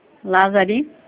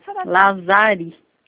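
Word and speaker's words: Lazari: Marta Lázari. – Marta como? – Lázari.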